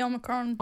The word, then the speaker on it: Omicron: So I'm thinking that is apparently a symptom of Omicron. How do you say it? Omicron. – It's the Omicron.